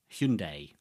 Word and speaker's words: Hyundai: The Hyundai, got to get the pronunciation right, like Sunday.